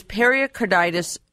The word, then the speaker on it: pericarditis: is pericarditis curable? And I hope I've said that right. Pericarditis, is that how you say it? – Yes, you did. Perfect.